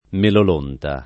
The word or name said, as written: melolonta